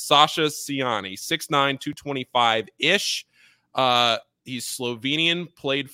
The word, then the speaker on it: Sasa Ciani: A Slovenian big man, Sasa Ciani, is how I was told, at least, that we were pronouncing this.